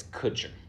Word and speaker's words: Kutcher: Okay, so it's Kutcher.